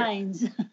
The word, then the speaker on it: Tynes: Tynes. – Tynes. Yes. That one's actually Tynes with an N.